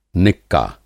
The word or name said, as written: निक्का